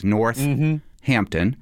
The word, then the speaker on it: Northampton: Northampton has one H but pronounces two H's. North Hampton.